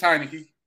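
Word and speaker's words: Heinicke: Heineke, was it Taylor Heineke? I don't want to mispronounce his last name. – It's Heineke.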